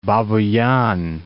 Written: Babuyan

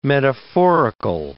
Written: metaphorical

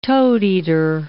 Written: toadeater

